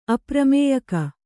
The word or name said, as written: ಅಪ್ರಮೇಯಕ